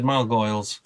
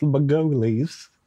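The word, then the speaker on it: Margolyes: Yeah, you said Margoyles. – It's because it's gargoyles. Remember? Remember the gargoyles? Remember Margoyles? Because it's spelt like gargoyles, but it's pronounced Margulies.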